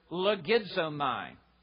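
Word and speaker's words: logizomai (λογίζομαι): I'm going to pronounce the word only so that you may hear an English word in it. It is the Greek word logizomai. And you can almost hear the English word logarithms in it.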